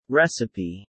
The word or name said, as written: recipe